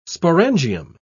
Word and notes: sporangium: British English

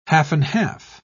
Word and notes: half-and-half: British English

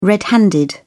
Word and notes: red handed: British English

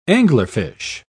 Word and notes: anglerfish: British English

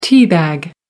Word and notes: tea bag: British English

